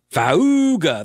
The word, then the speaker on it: Faauuga: I think I'm pronouncing that correctly. F A A U U G A, right? Fauga. That's what that is. That's what that says to me.